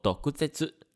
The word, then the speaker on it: 毒舌: 毒蛇呢，在日文中读作 doguzetsu。